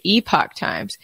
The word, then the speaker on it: Epoch Times: Guys, I love, I guess it's pronounced the Epoch Times. – It just depends if you're pronouncing in the Canadian way or American way. So either way is correct.